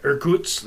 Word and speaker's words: Irkutsk: Irkutsk.